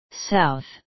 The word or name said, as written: south